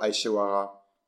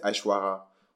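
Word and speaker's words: Aishwarya: Euh, moi, je dis toujours Aishawara, mais je prononce mal, mais je crois que c'est Aishwara.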